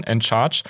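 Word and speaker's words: &Charge: Ähm, das e-, erste Beispiel ist ähm, End Charge heißen die, also das Unzeichen und dann Charge ähm, und äh, wird halt auf Englisch ausgesprochen, End Charge.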